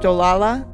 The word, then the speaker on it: Olala: Though it was in a town pronounced Olala, the way it was spelled made it look like ooh la la.